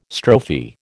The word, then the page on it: strophe: American English